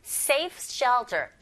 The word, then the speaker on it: safest shelter: 자, 자음이 연속적으로 세 개가 쭈르륵 붙어서 나왔습니다. 그럼 두 번째 자음의 소리 t 탈락. 그리고 s 또 겹자음이죠. 또 탈락. 그래서 결과적으로는 safe까지 발음하시고 shelter로 넘어가시면 훨씬 수월하게 발음을 할 수 있어요.